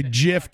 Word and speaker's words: GIF: And by the way, the guy who invented the GIF came out and said it's pronounced GIF.